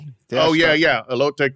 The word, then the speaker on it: elote: Alito. Alito. How did I say it? – Elote.